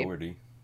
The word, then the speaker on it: Dougherty: How do you pronounce that? – I think you had it right. – I thought it was Dougherty. – Dougherty. Yeah.